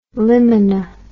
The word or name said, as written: limina